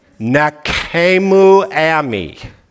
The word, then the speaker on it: Nachamu Ami: I see underneath that, and this might be the most important thing I see, Nachamu Ami. Nachamu Ami. I got news for you, folks. Most of you can't even say it right.